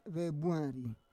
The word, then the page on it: vebuari: Roon